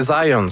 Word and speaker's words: Zajonc: Non, c'est Zajonc. – Ouais, OK. – Ouais, j'ai-tu pas dit Zajonc? – Vous avez dit plus Zajonc, mais. – Parce que j'ai prononcé un petit peu à l'anglais. J'ai prononcé mon polonais à l'anglais.